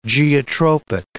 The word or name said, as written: geotropic